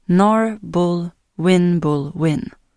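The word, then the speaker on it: Nabulwinjbulwinj: Det här är Narbol Winbolwin. Han är en farlig ande som äter kvinnor efter att ha slagit dem med en jams. Hans namn uttalas Narbol Winbolwin.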